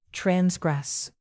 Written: transgress

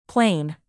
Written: plain

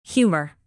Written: humor

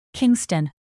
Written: kingston

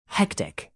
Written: hectic

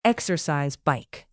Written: exercise bike

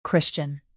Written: christian

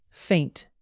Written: faint

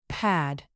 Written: pad

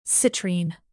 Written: citrine

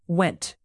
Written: went

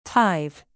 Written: tithe

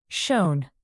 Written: shown

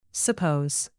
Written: suppose

